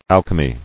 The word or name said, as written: Alchemically